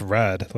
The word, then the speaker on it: rud: He's like, he has the regular dialogue and then he'll say like road, but it's like pronounced red, like R-U-D.